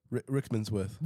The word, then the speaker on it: Rickmansworth: So today, for whoever doesn't know, Chenji has joined Moore Park Golf Club, which is in Rickenshaw. Is that how you pronounce Northwood? – It? – Northwood? – No, it's Rickenshaw. – Rickmansworth. – Rickmansworth. I'm not with it, man.